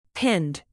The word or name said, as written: pinned